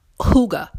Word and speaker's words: hygge: And this word is hygge, and it's spelled different than the way it sounds. It's spelled H-Y-G-G-E. H-Y-G-G-E.